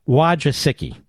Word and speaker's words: Wojcicki: Wojcicki.